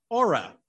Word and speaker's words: OHRA: We invite you to join the Overhyped Results Association, OHRA, pronounced Aura.